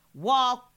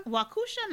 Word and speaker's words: Waukesha: It's pronounced Waukesha. – Waukesha? – Waukesha, not Waukesha. Thanks from a Wisconsinite.